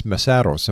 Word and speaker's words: Mesaros: It's MESAROS, am I right? – You're right.